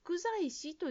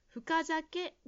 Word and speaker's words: 深酒: それともう一つ、深酒という言葉ですが、ジムさんは福在師と言っているように聞こえました。深酒が正しい発音です。